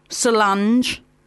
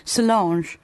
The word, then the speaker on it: Solange: Oh, God, am I saying it right? Solange. It's like, I think it's like Blancmange. Solange.